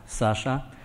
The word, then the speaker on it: Sasha: Well, my name is written Alexander, but it's pronounced Sasha. And this is because Sasha is nicknamed for Alexander,